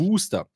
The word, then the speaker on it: Worcester: Aber es wird anders ausgesprochen, als es im Endeffekt auf dem Ortsschild steht, nämlich Richtig. Wooster.